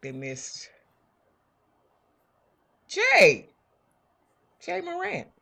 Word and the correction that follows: Ja Morant: Ja Morant? – Ja Morant. – Pronounce his name.